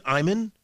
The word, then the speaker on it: Ayman: He, do I pronounce it Ayman? A-Y-M-A-N? I think that's how I pronounce his name. He is with MSNBC. Moheldin, M-O-H-Y-E-L-D-I-N.